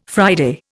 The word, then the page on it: Friday: Indian